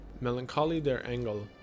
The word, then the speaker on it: Melancholie der Engel: Melancholie der Engel, which I took German class, yeah, that's how it's pronounced,